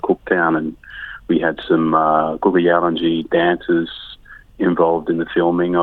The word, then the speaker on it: Kuku Yalanji: Yeah, now you've just released a track, a very, very beautiful track, Kuku Yalanji. Did I say it correctly? – Yeah, Kuku Yalanji. – Kuku Yalanji.